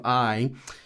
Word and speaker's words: Ai: Sometimes this is pronounced as Ai, but the Hebrew text, it's all together, the city of Ai.